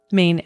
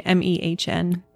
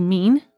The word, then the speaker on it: Mehn: And how is it? Mehn. M E H N. Mehn. – Mm-hmm. – Okay, I was curious because when I see it, I see mean. – Mm-hmm. Like, I yeah. don't know. I'm like, is that how you say it? Or am I saying it wrong?